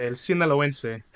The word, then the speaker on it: El Sinaloense: Yeah, El Sinaloense, which just means. – Um, oh, I did say it right. Okay, cool. – Yeah, no, you did.